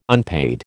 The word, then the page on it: unpaid: American English